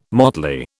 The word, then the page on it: motley: British English